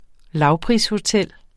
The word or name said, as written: lavprishotel